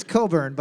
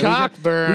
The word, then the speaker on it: Cockburn: Bruce, it's Coburn. But Cockburn. – We just like saying Cockburn because it's spelled that way.